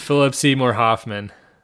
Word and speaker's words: Philip Seymour Hoffman: Robin Williams versus Fillmore Seymour Hoffman. Dude. Trying to mix up every syllable in that guy's name. Robin Williams versus Philip Seymour Hoffman.